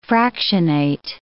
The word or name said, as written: fractionate